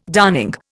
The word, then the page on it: darning: Indian